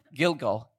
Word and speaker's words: Gilgal: And I here on the right side, you will see in the right circle, there is, I'm going to try to say this correctly, Gilgal. Is that right? Good. Gilgal, that was the headquarters where the Israelites were.